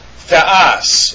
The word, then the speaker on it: Theos: Theos is how you pronounce that.